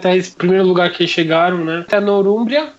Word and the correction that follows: Nortúmbria: se pronuncia assim, não é sei. Nortúmbria.